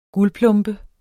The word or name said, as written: guldplombe